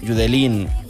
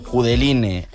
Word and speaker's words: Judeline: Y bueno, la historia de por lo que estamos escuchando esto es que Judelín, Judeline, Judeline, como la queramos llamar, porque todos los nombres son válidos.